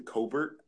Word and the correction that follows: Corbett: I think that's how you pronounce his name. I think he's going to be a great addition to the line. – It's Austin Corbett. – Corbett.